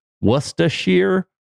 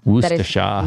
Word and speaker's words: Worcestershire: You never pronounce the first R, apparently. It's either Worcestershire or Worcestershire.